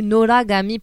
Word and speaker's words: Noragami: Alors, comment il s'appelle celui-là Ça s'appelle Noragami ou Noragami pour le prononcer à la japonaise.